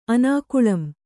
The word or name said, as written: ಅನಾಕುಳಂ